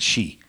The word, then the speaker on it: He: It's H-E, you pronounce it Xi.